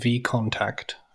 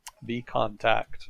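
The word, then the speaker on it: VKontakte: We did get a pronunciation tip. It is called V Contact, I believe.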